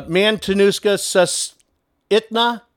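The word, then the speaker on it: Matanuska-Susitna: Mantanuska, Mantanuska, Shushita, uh, Mantanuska, Susitna, Borough. Man, man, Manatuska, Susitna, Borough.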